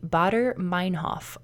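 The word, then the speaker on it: Baader-Meinhof: It is called the Bader-Meinhof phenomenon.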